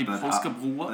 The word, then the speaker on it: påskebrød: How would you say this in Danish? You'd say påskebrød. – In Danish. – So påskebrød. – You're the Danish expert right here. – Yeah. It'll be påskebrød.